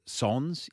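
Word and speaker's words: sans: Is that how you say it? Sons? – Sons, yeah. Avec is with and Sons is without.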